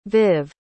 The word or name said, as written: viv